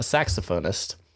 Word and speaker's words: saxophonist: Saxophonist. – Is that how you say it? – I believe so. – Oh, okay. Well, might depend where what country you come from.